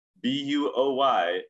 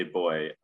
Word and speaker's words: buoy: She can say with absolute authority that they do not pronounce B U O Y as boy in the United States. And I have to say, Alice is right. I got it reversed. The British say boy, Americans say buoy.